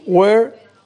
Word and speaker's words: were: Y were, pues también hay que tener mucho ojo, porque es muy parecida a, por ejemplo, where, ¿no? Where significa dónde, pero es where, como con V-A, where.